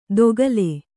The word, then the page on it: ದೊಗಲೆ: kannada